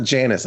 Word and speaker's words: Janus: But it's not Janus, like J-A-N-I-C-E. It's Janus, J-A-N-U-S.